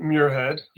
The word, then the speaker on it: Muirhead: So my name's Todd Muirhead.